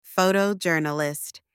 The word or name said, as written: photojournalist